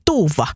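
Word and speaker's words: Dufva: Missä teoksessa on hahmo Sven Duva, että se olisi väärin, että pitäisi olla Sven Tuva, niin ei tarvii olla. Kyllä se Sven Duva on se alkuperäinen hahmo ja sitten kun suomalaisille tuommoinen Duva on liian vaikea ääntää, niin se sitten vielä suomennettiin Sven Tuuvaksi.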